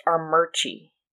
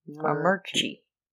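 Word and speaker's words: Armuchee: By the way, it's pronounced Armurchie. – Armurchie. Okay. – Even though there's no R right here, but it's Armurchie.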